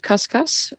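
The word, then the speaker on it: cuscus: Recently, this creature, the kuskus, was rescued. First of all, what is a kuskus? Am I pronouncing it right? – No, it's pronounced kaskas, and it's an indigenous native animal to Papua New Guinea.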